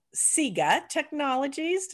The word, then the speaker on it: Siga Technologies: Currently, Julie is a member of the board of directors of Siga Technologies.